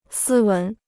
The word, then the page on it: 斯文: sī wén